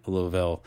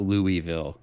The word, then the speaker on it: Louisville: Louisville's own White Reaper, and I hope I I hope I don't get busted for saying Louisville uh, correctly or trying to sound like I know how to say it, but it's not Louisville, it's Louisville. For all you uh,